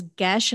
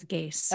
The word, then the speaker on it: geas: The geese, G-E-A-S. It's like the- I've heard it as gesh and I've heard it as geese.